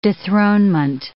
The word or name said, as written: dethronement